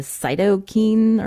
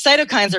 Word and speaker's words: cytokine: I think some people might have seen this in print, the cytokine or keen storm. Is that how it's said? – Yes, cytokine storm.